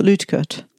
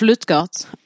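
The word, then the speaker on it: Luetgert: Och en man då som har kommit till Chicago från Tyskland för att lyckas hette Adolf Lütkert. Adolf Lutgert. – Adolf Lutgert. – Ah, ja, det har du.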